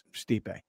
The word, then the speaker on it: Stipe: Like, I could have very easily corrected him off the air and be like, yo, man, the pronunciation is Stipe. He's like, no. I'm like, hey, Lawhead, how'd you like that picture of me and that UFC guy? And he's like, you mean Stipe Miocic?